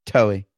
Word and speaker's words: Toei: Toei, Toei, Toei. – It's like Joey, but Toei.